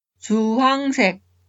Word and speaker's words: 주황색: 주황색 주황색 naranja 주황색 La consonante hit es un sonido débil. Algunas personas pronuncian 주황색, pero la pronunciación estándar es 주황색.